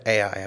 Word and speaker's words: Ai: And that's how I'm going to pronounce it, Ai. I've pronounced it that way forever. It's pronounced different ways.